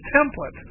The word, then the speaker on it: Templet: It's spelled T-E-M-P-L-E-T. It is a French Cajun, that is from her French Cajun heritage. Her parents, it comes from her father, her parents did not mind the fact that the anglicized version of it was pronounced Templet. She didn't accept that. She wanted to be pronounced the way that a French person, a French speaker, would pronounce it. So she pronounced it Templet.